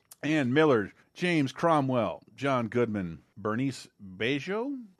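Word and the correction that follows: Bérénice Bejo: uh, and Jean. Oh boy, how do you say that? – It was Berenice Bejo and Jean Dujardin.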